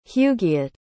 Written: hugiet

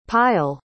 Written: payal

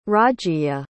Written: Rajiya

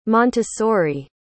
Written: montessori

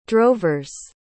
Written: drovers

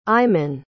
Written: aimon

